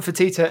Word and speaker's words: Fertitta: Tillman Fertitta and his investments and the stuff that's planned for developing the south end of the strip.